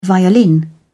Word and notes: violin: British English

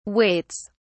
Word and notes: waits: British English

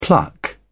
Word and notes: pluck: British English

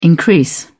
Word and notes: increase: British English

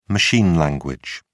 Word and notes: machine language: British English

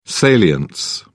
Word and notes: salience: British English

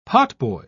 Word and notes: potboy: British English

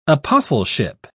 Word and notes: apostleship: British English

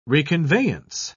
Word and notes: reconveyance: British English

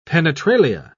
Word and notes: penetralia: British English

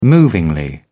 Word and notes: movingly: British English

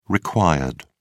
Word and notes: required: British English

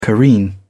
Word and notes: careen: British English

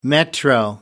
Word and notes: metro: American English

